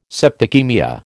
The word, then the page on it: septicaemia: American English